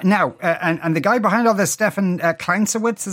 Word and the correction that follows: Klinsiewicz: is that how you pronounce it? – Klinsiewicz, I believe. – Klinsiewicz. Okay, fair enough.